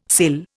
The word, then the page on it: cill: Indian